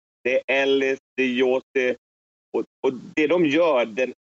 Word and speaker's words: Josi: Jag har jobbat i Schweiz. Jag säger Roman Josi.